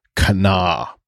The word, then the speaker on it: Kanawha: But the proper pronunciation is Kana. It's not Kanawha or Kanawha or however she said it. It is typically two syllables.